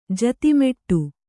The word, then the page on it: ಜತಿಮೆಟ್ಟು: Kannada